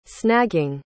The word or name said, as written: snagging